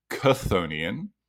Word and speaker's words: Chthonian: I actually figured out that it is pronounced Chthonian, because in Greek it's spelt with the chi letter at the front, which looks like the letter X.